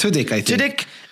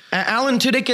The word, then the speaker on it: Tudyk: Apparently, Alan, I don't know how to say it. – Tudyk. – Alan Tudyk is voicing Iago.